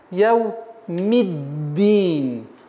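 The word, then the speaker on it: яу миддин (يوم الدين): Так как даль является солнечным звуком, автоматически артикль не читается. Итого получается у нас яу миддин. Нужно немножко давить, так как при отсутствии давления на местонахождение буквы даль получится т.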